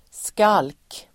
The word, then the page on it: skalk: [skal:k]